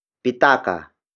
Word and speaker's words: pitaka: The next word is pitaka. Pitaka. Pitaka. Pitaka is spelled as P-I-T-A-K-A.